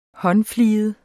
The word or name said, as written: håndfliget